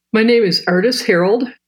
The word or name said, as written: Ardis Herrold